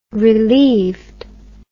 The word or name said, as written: relieved